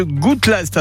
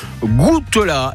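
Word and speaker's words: Goutelas: Tout le programme complet est à retrouver sur le site internet du château de Gouttelast. Et vous écrivez ça G-O-U-T-E-L-A et il y a un S à la fin. On ne prononce pas Gouttelast, mais il y a un S.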